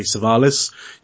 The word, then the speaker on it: Savalas: I was saying that correctly. Savalas.